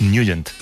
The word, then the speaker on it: Nugent: Hombre, siempre lo hemos llamado en el barrio Ted Nugent o Ted Nugent. – Claro, yo era de Ted Nugent. – Pero la pronunciación correcta es Nugent.